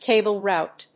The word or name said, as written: cable route